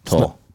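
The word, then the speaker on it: Towle: You got to spell that out because they're going to put T-O-L-E. – Right. It's T-O-W-L-E. Ryan_ Toll.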